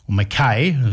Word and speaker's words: Mackay: And Australian Test cricket coach Andrew McDonald isn't ruling out changes for the second match against Bangladesh in Mackay. McKay, as some of the locals say, but he says they won't make knee-jerk reactions to their loss in Darwin.